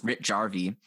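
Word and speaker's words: Rautjärvi: No, that's how you pronounce it. It's Ritjärvi.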